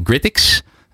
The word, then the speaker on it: GritX: Uh, jij bent de eigenaar van Gritix.